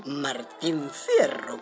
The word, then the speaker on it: Martín Fierro: ¿Usted sabía que un primero de marzo, pero de 1879, apareció la segunda parte del Martín Hierro? – Martín Fierro, querrá decir, Catita. – Ay, eso lo dirá usted en su ignorancia, Pepe. Pero en buen cayetano se dice hierro, no fierro.